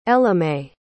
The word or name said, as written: ellamae